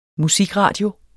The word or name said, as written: musikradio